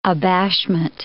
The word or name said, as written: abashment